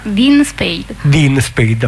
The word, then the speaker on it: Dean Spade: come con questo mutuo appoggio di Ian Spade, non lo so come si pronuncia. – Credo sia Dean Spade, appunto. – Sì, esattamente, guarda, è proprio così, ma il percorso di questo libro in realtà racconta anche il modo in cui lavoriamo da anni con la rivista.